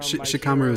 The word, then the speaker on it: Shikamaru: So the him, and then I would take from Shikamaru is the Japanese pronunciation.